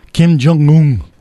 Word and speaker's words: Kim Jong-un: How do you say his name? Kim Jong-un, right? – Yeah.